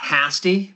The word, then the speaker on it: Hasty: And I believe it's pronounced Hasty, because it rhymes with nasty.